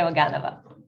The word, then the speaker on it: Oganova: Eugenia Oganova.